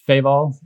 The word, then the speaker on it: Feval: It's by a guy named Charles Feval, F-E-V-A-L, accent on the E.